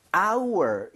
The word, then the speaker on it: our: 아, 참고로, our 이라고 발음하는 게 맞아요. – Yeah. – 맞는데 빠르게 발음하면, 얘를 alphabet R처럼 아. – 대충 발음하는 경우가 생길 수밖에 없어요. 그래서 이거를 너무 our 하려고 it's our 이렇게 할 필요 없고요. It's our mistake. It's our mistake. 거의 r처럼 한번 부드럽게 해보세요.